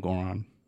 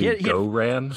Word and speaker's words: Goran: They call him they call him the dragon, but I think that's just because his name starts with D-R-A-G, but I don't know. – I just call him Goran. – Okay. – And hopefully I'm pronouncing that right. he had 14 in the fourth. – It's actually Goran.